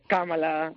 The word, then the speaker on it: Kamala: ¿Se dice Kamala o Kamala? ¿Cómo hay que pronunciar el nombre? – Kamala. – Kamala.